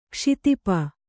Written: ಕ್ಷಿತಿಪ

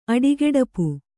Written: ಅಡಿಗೆಡಪು

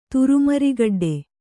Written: ತುರುಮರಿಗಡ್ಡೆ

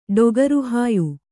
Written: ಡೊಗರುಹಾಯು

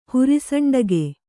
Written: ಹುರಿಸಂಡಗೆ

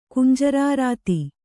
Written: ಕುಂಜರಾರಾತಿ

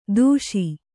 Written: ದೋಷಿ

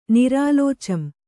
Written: ನಿರಾಲೋಚಂ

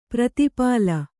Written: ಪ್ರತಿಪಾಲ

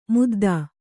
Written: ಮುದ್ದ

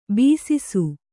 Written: ಬೆಸಸು

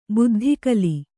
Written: ಬುದ್ಧಿಗಲಿ

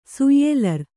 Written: ಸುಯ್ಯೆಲರ್

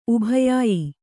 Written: ಉಭಯಾಯಿ

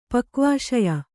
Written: ಪಕ್ವಾಶಯ

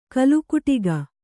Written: ಕೋಲುಕುಟುಕ

